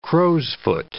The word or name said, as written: crow's-foot